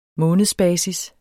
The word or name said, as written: månedsbasis